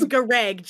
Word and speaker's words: Gareg: The name was Gareg. GAREGG. Gareg. – Isn't that Greg, how it's spelled? – Gareg.